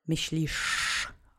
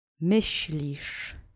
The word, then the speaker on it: Myślisz: Myślisz. Okay? Accent is on the first syllable. Let's say it together three times. Myślisz.